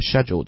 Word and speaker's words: schedule: 在这里又是这个 schedule 这个词啊，因为可能有很多新的朋友在听，我们就再把这个词的读音给大家重新再讲一遍。你一般情况下听的最多的这个读法应该是美式英语的读法，叫 schedule, okay, so, Do you have a very busy schedule today, Mary?